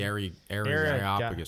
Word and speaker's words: Areopagus: Yeah, that's great, though, that also in Acts, you know, Paul shows up there at Areopagus and it's like, hey, did I pronounce that right? Areopagus. – The Areopagus.